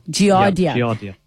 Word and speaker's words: giardia: I'm not sure how to pronounce this word, doctor, but the person says, I had giardia. Is that how you say it? – Giardia.